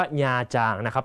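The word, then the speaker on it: Nha Trang: คำนี้ต้องอ่านให้ถูกนะฮะอ่านว่าญาจางน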